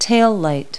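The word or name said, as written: taillight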